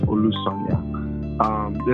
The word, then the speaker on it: Olusanya: And the last name is Olusanya.